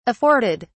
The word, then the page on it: afforded: British English